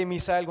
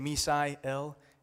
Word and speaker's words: Misael: And if you can't say Misael Gonzalez, that's fine. I won't force an accent on you. You can say Misael, it's fine. So if you're new, now you know how to say my name.